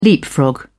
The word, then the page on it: leapfrog: British English